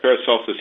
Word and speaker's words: Paracelsus: Did I pronounce that right? Yeah. – Yeah, that's correct. Paracelsus.